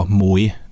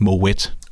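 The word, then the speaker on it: Moët: En sjov lille note, man egentlig kunne nævne, det er jo, at det, som folk kalder for moe, det udtales faktisk moet, fordi det er et hollandsk eget hus i Præcis.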